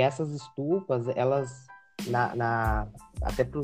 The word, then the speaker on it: estupas: E aí, né, posteriormente, nessas estudas, como eles chamavam, eu não sei se pronuncia estudas mesmo, é estupas, né, quer dizer.